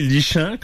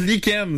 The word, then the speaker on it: lichen: quelque chose qui se lit lichant, comme disait Marion. – Ben ouais, tu... – Pourquoi on le prononce lichen? C'est quoi le rapport? – Ça devrait être écrit comme L-I-K-E-N-N-E.